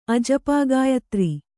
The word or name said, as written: ಅಜಪಾಗಾಯತ್ರಿ